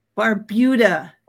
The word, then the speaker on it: Barbuda: Barbuda, not Barbuda.